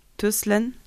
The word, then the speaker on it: Tyskland: Tuslan. – Tuslan. – Yeah. Tuslan. – Le Y en suédois est prononcé UI.